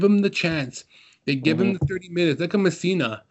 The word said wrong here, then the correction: Pessina: Messina's been flourishing this season. Pessina, thank you.